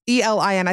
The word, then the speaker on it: Elin: I mean, I know it's E L I N. I think it's Elin is how you say her name.